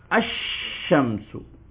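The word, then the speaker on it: அஷ்ஷம்சு (الشمس): பாருங்க, அஷ்ஷம்சு சத்து வைக்கும், அஷ்ஷம்சு அஷ்ஷம்சு பாருங்க, அர் ரஹ்மான் அஷ்ஷம்ஸ் அன்னஜம் அஷ்ஷஜர். இதுல என்ன வைக்கும், சத்து வைக்கும்.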